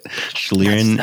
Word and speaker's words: Schlieren: Schlieren, is that how you say it? Schlieren. – That's right.